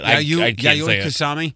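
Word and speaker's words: Yayoi Kusama: Did I get close? – No, that's how you say it. – Yayoi Kusami? – Mm-hmm.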